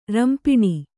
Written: ರಂಪಿಣಿ

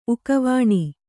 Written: ಉಕವಾಣಿ

